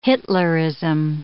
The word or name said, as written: Hitlerism